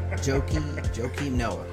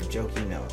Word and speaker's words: Joakim Noah: Like, if they came out with a new movie, if they redid a reboot of the Equalizer, Is Joker Nicole, whatever his first name is, I can't even pronounce that. Joker. – Yeah. Joakim Noah. That's the name. Joakim Noah.